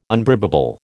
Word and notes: unbribable: American English

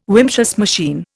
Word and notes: Wimshurst machine: Indian

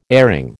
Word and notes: airing: American English

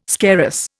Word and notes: scarus: Indian

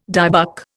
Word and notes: dybbuk: Indian

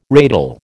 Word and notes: ratel: American English

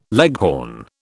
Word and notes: leghorn: British English

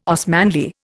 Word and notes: Osmanli: Indian

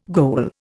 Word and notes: goal: Indian

